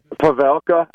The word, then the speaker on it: Pavelka: Is that how you say his last name? – Yeah, how do you say it? – I like how you said that. I think it's Pavelka, right? – Pavelka? Yeah, the pilot.